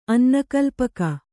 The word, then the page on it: ಅನ್ನಕಲ್ಪಕ: kannada